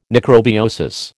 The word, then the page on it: necrobiosis: American English